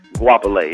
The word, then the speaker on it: Guapale: That's how you say her name? – Guapale, yeah. – I was trying to figure out, I was thinking like Go Apple or something, you know what I'm saying?